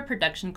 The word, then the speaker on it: production: Notice here that the letter O has yet a different pronunciation in the word production. Production, production, production, right? This sound, this sound is what we call the schwa sound, right?